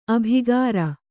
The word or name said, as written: ಅಬುಕಾರಿ